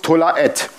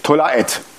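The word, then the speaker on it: Tola Et (תולעת): Und das Interessante ist, das hebräische Wort für diesen Wurm heißt Tola At. Das schreibt man T-O-L-A Apostroph A-T-H. Spricht man aus Tola Et. Tola Et.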